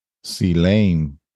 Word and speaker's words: Caelum: Caelum is pronounced. It's pronounced Caelum. Caelum.